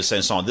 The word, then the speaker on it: Saint-Saëns: Yes, we go all the way into the 19th century with Camille Saint-Saëns.